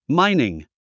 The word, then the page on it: mining: mǽiniŋ; マァイニン